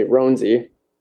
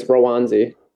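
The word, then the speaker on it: Roansy: Well, I think we used to say Ronzi, which is super white of us. – Yeah. – But I'm pretty positive it's Rowanzi.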